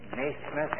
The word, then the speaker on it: Naismith: I guess it's not very common in these parts, the name Naismith, N-A-I-S-M-I-T-H.